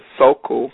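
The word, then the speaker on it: Sokule: And for people who want to check out SoCool, it's spelled S-O-K-U-L-E. I used to say SoCool, but it's SoCool.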